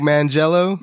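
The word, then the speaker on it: Manganiello: Joe Mangello.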